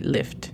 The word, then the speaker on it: lift: I should leave. – Yes. Tú. – You should leave. – Recuerda de pronunciar la T al final de lift. Porque si no se escucha como live, de vivir.